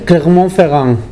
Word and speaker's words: Clermont-Ferrand: Clermont-Ferrand, va bene, che è la sede principale, però anche Vienna, Atene, Zagabria, poi Vilnius, Bruxelles, insomma.